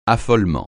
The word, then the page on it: affolement: French